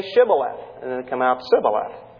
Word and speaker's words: Shibboleth: And they were unable to pronounce the SH sound in that word, Shibboleth. And whenever they pronounced it, it came out Sibboleth.